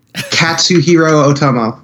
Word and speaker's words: Katsuhiro Otomo: I can never pronounce his name right. Katsuhiro Otomo. – Nailed it.